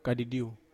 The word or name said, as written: kadidiu